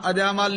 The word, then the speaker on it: अजामल: अजामल पापी जग जाने, तो साध संगत जी अजा अड ते मल अड कहांगे। अजा दा नाम, अजा दा अर्थ हुंदा बकरी ते मल दा अर्थ हुंदा पहलवान, ते अजामल कहांगे ओह भी नहीं गल बनदी। जिवें संप्रदायी उच्चारण है अजामल पापी, ओहदा नाम है इंसान दा, ओहदा नाम अजामल ही है।